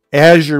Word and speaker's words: Azure: And in case you've ever wondered how to pronounce it, Mark Rusinovich once told me to say, as you're moving your on-premises applications to the cloud.